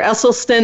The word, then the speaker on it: Esselstyn: Dr. Esselstyn, am I saying your name right now? – Oh, it's perfect now.